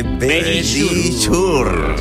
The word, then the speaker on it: Verisure: Nuestros amigos de Verisure. – Ahí lo habéis dicho divinamente.